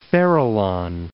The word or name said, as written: Farallon Islands